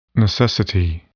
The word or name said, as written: necessity